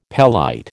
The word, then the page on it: pelite: American English